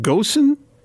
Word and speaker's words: Ghosn: So this guy's name is Carlos, is it Ghosn? Is that how you say it? Are you aware? Apparently, okay.